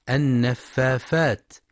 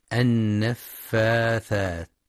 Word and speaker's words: النفاثات: وفي قوله تعالى ومن شر النفاثات في العقد، هناك خطأ شائع في كلمة النفاثات خاصة عند صغار السن، حيث يبدلون الثاء فاء النفافات، فلينتبه لنطقها الصحيح بالثاء النفاثات.